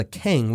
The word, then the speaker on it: cangue: in what's called a keng, which was this heavy wooden collar that criminals had to wear around their necks. This is worth a Google. It's spelled C-A-N-G-U-E.